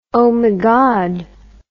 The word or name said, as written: omigod